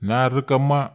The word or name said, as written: nar reka ma